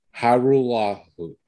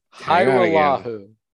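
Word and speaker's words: Hajrullahu: Yeah, according to Michael Gelkin of the Dallas Morning News, it's Liram Hirulahu. – Hirulahu is how you're going to say that. – Hirulahu, yeah.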